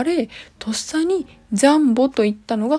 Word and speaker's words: 両棒: なんで両方の棒で当時からジャンボって読んだんですかね。このあ書いてありましたこの両方の量に棒をジャンボと読むのは中国から来ている